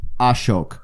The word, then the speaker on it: Ashok: I'm like, so Ashok. He's like, it's Ashok. I'm like, Ashok. Oh, okay, cool.